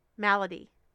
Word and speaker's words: malady: Hers is called An Unusual Milady. Malady? Milady? Look at her using words I can't pronounce. Milady? Malady? Milady? Malady. An Unusual Malady.